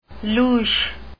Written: louche

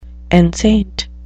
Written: enceinte

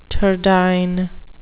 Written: turdiform

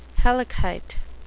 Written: hellkite

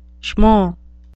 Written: schmo

